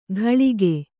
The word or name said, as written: ಘಳಿಗೆ